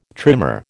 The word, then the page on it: trimer: American English